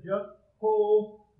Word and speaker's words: Jehovah: And then you have the J sound here with the little EH, and then the H, and then the long O came from here, and this vowel here, and these letters here. So you come up with a Jehovah.